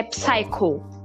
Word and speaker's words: Psycho: já assistiram, mas tem uma série, tem um filme na Netflix que se chama, eu acho que é Psycho, ou tem outro nome que eu, é, é eu não sei se é assim que se pronuncia. – Sim, é.